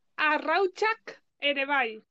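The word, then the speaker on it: arrautzak ere bai: Entonces, y por ejemplo, estoy tan, sería, también estoy hasta los huevos, sería arrauchat erebai. – También estoy hasta... – hasta los huevos. – vale, ¿cómo se dice esto? ¿Dices? – Arrauchak Erebai. – Vale, ¿y se escribe? – A-R-R-A-U-T-Z-A-K espacio E-R-E e espacio B-A-I.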